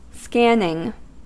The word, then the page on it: scanning: American English